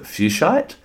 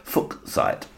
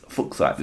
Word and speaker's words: fuchsite: Some people pronounce it fuchsite. It's spelled F-U-C-H-S-I-T-E. But it's actually, I believe it's German surname of the gentleman who discovered it, fuchsite. Sounds a bit rude, but that's what it is, fuchsite.